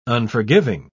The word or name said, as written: unforgiving